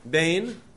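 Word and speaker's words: Bain (בֵּין): Bain, not Ben, Bain.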